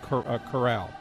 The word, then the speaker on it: Corral: C-O-R-A-L. – And you pronounce it? – I believe it's pronounced Corral. – Okay. Like the horses in the corral.